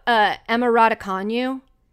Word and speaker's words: Emma Raducanu: Can we talk about Emma Raducanu? – So thank you for pronouncing her last name.